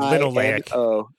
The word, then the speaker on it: linoleic: Linoleic.